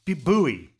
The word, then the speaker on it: buoy: So buoy, B-U-O-Y. It's a funny spelling, right? In North America, it's pronounced buoy.